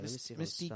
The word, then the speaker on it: Misty: Is it Misty Crockett or Missy Crockett? – There's a T in there. – Okay, Misty Crockett. – And I feel like it's spelled K-R-A-C-K-E-T.